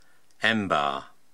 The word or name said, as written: Embar